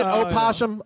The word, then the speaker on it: opossum: Now, isn't it opossum? Opossum? – It's spelled O-P-O-S. – But the O is silent? – The O is silent.